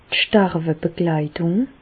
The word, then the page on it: d' Sterwebegleitùng: Bas Rhin; Reichshoffen